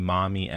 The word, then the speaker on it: Marmee: But those with a more New England accent would actually pronounce the R as ah. So it would be mommy anyways.